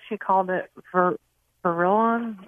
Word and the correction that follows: verticillium: or verrillium. I'm not saying it right. – Probably verticillium. – Thank you. That's it.